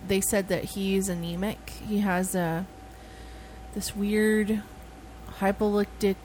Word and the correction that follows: hemolytic: some kind of, I can't say it right, but it's some anemia. – Autoimmune hemolytic anemia. – Yeah, that's it.